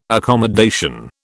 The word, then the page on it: accommodation: British English